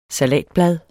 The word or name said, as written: salatblad